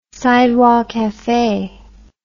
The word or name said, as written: sidewalk cafe